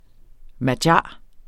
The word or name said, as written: magyar